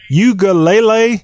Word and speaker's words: Uiagalelei: You got to help us pronounce names here. DJ Uiagalelei is the latest Clemson quarterback commit coming from California. – You didn't need my help at all. I think that's exactly right.